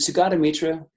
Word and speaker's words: Sugata Mitra: And I think one of the things that, is it Sugata Mitra? Is that am I getting his pronunciation right? – You are.